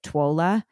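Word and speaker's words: TWLOHA: It's an organization called To Write Love on Her Arms, which is, I think I heard them pronounce it TWOLA.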